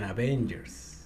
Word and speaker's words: Avengers: lo pronunciaste bien, Avengers.